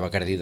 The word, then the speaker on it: Bacardí: ara el que crida més l'atenció és l'accent tancat a la I de Bacardí. Per tant, ara diguem-ne que el logo ja apela a la pronúncia catalana del nom, aquest Bacardí.